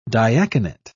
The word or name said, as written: diaconate